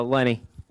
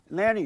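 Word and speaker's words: Leni: Uh, Lenny. Lenny.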